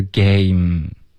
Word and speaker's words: game: OK，比如 game 这个字游戏，它的结尾呢只是一个没了，没有一个 m。 OK，虽然它的就是结尾有一个 e 的这个英文，可是它是不发音的，所以它的发音是 game，有一个就够了。然后这个字母它的说法呢，就是中文我们说什么 m 或者什么 m，这个就很不一样了。